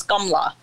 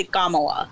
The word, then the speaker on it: Kamala: And it's basically Kamala. That's how she pronounces it.